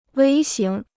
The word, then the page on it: 微型: wēi xíng